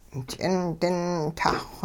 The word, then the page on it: ch'indin-tahxw: hupa